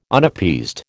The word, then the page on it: Unappeased: American English